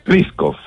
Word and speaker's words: Pliskov: Pliskov. – Pliskov, ya, perfecto. – Tal como suena nomás.